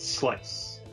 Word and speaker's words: Slice: Oh, it's just Slice. Slice. Like pizza Slice.